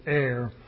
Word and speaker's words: err: In other words, it cannot err, which is the pronunciation of err.